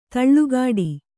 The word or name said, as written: ದಳೆಕಟ್ಟು